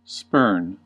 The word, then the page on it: spurn: American English